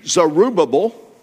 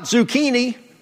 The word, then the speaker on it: Zerubbabel: Now, you might pronounce this name Zerubbabel. You might pronounce it Zerubbabel. And if you're reading that to somebody, I believe they can understand one of those ways, but his name was not Zucchini.